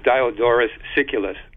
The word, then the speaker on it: Diodorus Siculus: And by the way, the name of that person I was trying to pronounce before, I was having a little trouble, is Diodorus Siculus.